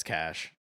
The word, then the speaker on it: cache: I never know how to pronounce that word. C-A-C-H-E. – Cash. That's cash. I call it cash. If it's cachet. – Yeah, that's flavor. – Yeah, I would say cash too.